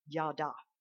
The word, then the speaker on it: Yada: Actually is how you pronounce it in Hebrew, Yada.